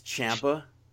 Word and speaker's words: Ciampa: It's Ciampa.